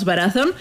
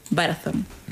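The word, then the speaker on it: Varathron: Eh, con esto de las recomendaciones y los griegos blaquerillos llamados Barazón,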